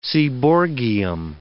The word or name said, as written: seaborgium